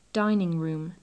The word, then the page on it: dining room: English